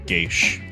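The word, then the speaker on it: geis: I just want to point out real quick that it's pronounced Geish, by the way. – Is it pronounced Geish? – Yeah. – No, I've always pronounced Geas? – I've always heard it pronounced Geish.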